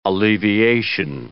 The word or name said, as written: alleviation